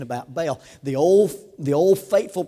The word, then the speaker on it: Baal: And probably more correctly pronounced as Baal, but in the south we call him Baal.